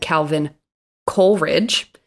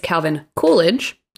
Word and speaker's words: Calvin Coolidge: I kept going back and forth between saying Calvin Coolidge and Calvin Coleridge. The correct way to say it is Calvin Coolidge.